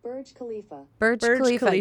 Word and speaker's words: Burj Khalifa: Burj Khalifa. – You were super close. – I was super close. – Yeah, Burj Khalifa.